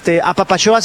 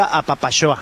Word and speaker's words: Apapaxoa: Apapachoa se escribe con X, se escribe apapaxoa, pero se pronuncia apapachoa.